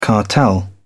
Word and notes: cartel: British English

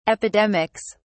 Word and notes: epidemics: British English

